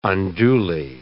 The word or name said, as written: unduly